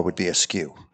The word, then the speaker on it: SKU: So looking at a brand, which is the best-selling SKU with that company, SKU, pronounced SKU, means stock-keeping unit used by retailers to track inventory.